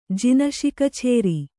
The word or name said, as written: ಜಿನಶಿಕಛೇರಿ